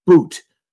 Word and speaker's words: Bout: Viktor Boot, spelled B-O-U-T, but pronounced as if it were B-O-O-T.